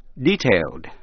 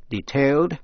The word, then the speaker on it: detailed: Cũng nhận xét chữ detailed, tính từ D-A-T-A-I-L-E-D, có hai cách đọc. Detailed hay là detailed, là chi tiết.